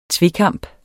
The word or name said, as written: tvekamp